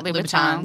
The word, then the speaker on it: Louboutin: Oh, yeah, Louboutins.